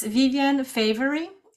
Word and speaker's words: Viviane Favery: And for me, it's Viviane Favery, V I V I A N E F A V E R Y.